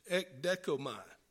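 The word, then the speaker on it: ekdekomai (ἐκδέχομαι): And the word means, the pronunciation of it in the Greek is ekdekomai, and the meaning is to await eagerly.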